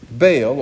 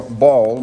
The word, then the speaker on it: Baal: The sacrifice was not successfully offered up because Baal, or Baal, more properly pronounced, was not answering.